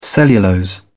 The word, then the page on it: cellulose: British English